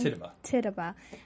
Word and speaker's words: Tituba: I think Tituba, am I still saying that wrong? – Tituba.